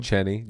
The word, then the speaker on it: Cheny: Cheny.